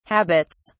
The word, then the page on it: habits: British English